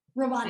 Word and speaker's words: Rwanda: Now, is this all still in your, and I can't say it right, your Rwanda? – Rwanda. – Am I saying that right? Yeah.